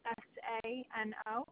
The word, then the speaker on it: Siracusano: SIRACUSANO.